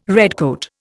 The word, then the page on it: redcoat: Indian